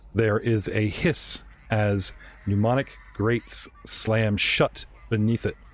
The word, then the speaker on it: pneumatic: Pneumatic. Spelt like phlegm. P-N-E-U, pneumatic.